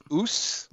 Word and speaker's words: Aass: One Norwegian beer spelled A-A-S-S, which is pronounced oos.